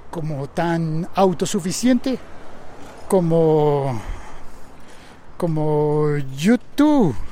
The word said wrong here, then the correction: YouTube: Lo dije mal, ¿verdad? Lo dije como si fuera la banda irlandesa, pero no, es YouTube.